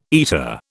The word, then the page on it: eta: British English